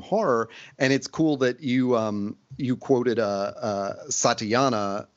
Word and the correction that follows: Santayana: was that how you say it? Santayana. – Yes.